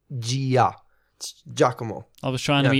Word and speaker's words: Giacomo: Giacomo? – No, it's Giacomo.